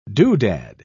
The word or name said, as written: doodad